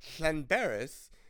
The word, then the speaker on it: Llanberis: That's because Llanberis is how you pronounce that name, because it's a double L-L. It's L-L-A-N-B-E-R-I-S, I think.